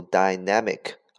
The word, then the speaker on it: dynamic: dynamic 这个单词的发音有两个，可以读 dynamic 啊，很好听，dynamic，也可以读 dynamic，dynamic，它的意思是有活力的，充满动力的啊，dynamic，dynamic。